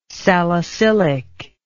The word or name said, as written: salicylic